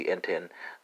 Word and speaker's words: entendes: So it's going to be entendes, spelled E-N-T-E-N-D-E-S in Portuguese.